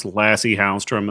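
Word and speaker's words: Lasse Hallström: It's pronounced Lassie Hallstrom, and there's a famous dog. I don't know if you guys have heard of the dog. It's called Lassie.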